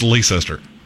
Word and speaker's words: Leicester: If you want me to say Leicester, spell it L-E-S-T-E-R. That's not that difficult.